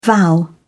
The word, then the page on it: vow: British English